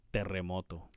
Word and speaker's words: Terremoto: You better be good with your double R's. Terremoto. – Wait, what? – Terremoto.